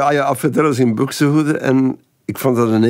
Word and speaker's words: Buxtehude: En ik heb die LP van Alfred Deller zingt op de A-kant muziek van Buxtehude. Ik weet niet hoe je het moet uitspreken. – Ja, dat is helemaal juist. – Buxtehude of Buxtehude.